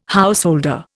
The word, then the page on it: householder: Indian